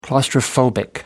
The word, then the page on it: claustrophobic: American English